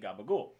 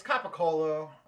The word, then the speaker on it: capicola: And they talk a lot in the show about the deli meat Gabagool. – That's not how it's spelled or should be pronounced. – It's Capicola. That's it.